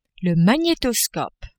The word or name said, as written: magnetoscope